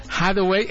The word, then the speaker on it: Hathaway: Viene esta segunda temporada donde de verdad Anna Hathaway, ¿cómo se pronuncia, mi queridísima? Hathaway, efectivamente, yo no sé el inglés, estudié en una escuela de la 4T, por eso no me alcanzó.